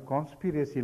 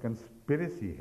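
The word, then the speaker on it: conspiracy: بات اتنی سی تھی کہ آپ نے, آپ کو تلفظ کے صحیح ادائیگی کا شوق ہے میں جانتی ہوں, مگر آپ نے کانسپیریسی لفظ بولا تھا ایک جگہ, تو یہ درست نہیں ہے, یہ لفظ کنسپیریسی ہے, تو یہ درست ہے ان کی بات.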